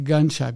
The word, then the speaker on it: Genshai: It's kind of like a hard G, almost like Gun Shai, but Gen Shai, G-E-N-S-H-A-I.